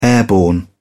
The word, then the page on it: airborne: British English